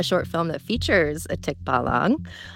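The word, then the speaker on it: tikbalang: Tikbalong. You're close. – Oh, okay. I got to have that accent on there. It's what it is, right? – Yeah, it's on the second syllable.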